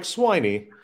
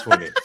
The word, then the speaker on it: Swiney: Is it Swinney or Sweeney? Nick Sweeney, right? – It's pronounced Sweeney. Nick Sweeney. It looks like Swiney, but he's not a pig. Yeah, it is Sweeney.